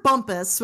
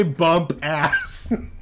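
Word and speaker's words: Bumpass: Roger Bumpus, which is fucking hilarious. That's his man's name. – I wanted to say bump ass. Because that's how it's spelled.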